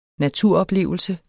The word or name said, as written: naturoplevelse